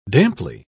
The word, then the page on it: damply: British English